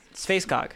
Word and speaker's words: Sveaskog: S-V-E-A-S-K-O-G. – You're probably pronouncing it wrong. – Probably. – How do you Christian want me to pronounce it? – Spacecog. – Spacecog. So it does end in cog.